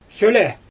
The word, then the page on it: sølɛ: Xyzyl